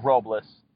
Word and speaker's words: Robles: It's Francisco, that's my full name, Francisco Robles, R-O-B-L-E-S.